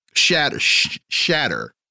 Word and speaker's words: Schnatter: The founder of Papa John's Pizza, John Schnatter, however you pronounce his name, he resigns after Schnatter. There we go.